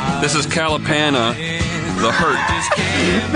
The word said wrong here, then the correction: Kalapana: That's the most Michigan pronunciation of a Hawaiian word I've ever heard. – Kalapana. – Kalapana. – Kalapana. This is Kalapana. – Kalapana. – Sorry, Kalapana.